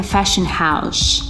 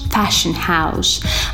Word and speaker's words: fashion house: Первое словосочетание будет a fashion house. Fashion house. Обратите внимание на то, что H я не произношу.